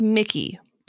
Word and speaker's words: Micajah: Well, it's pronounced Mickey, but it's spelled M-I-C-A-J-A-H. – Ew.